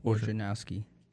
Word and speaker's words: Wojnarowski: Wojnarowski.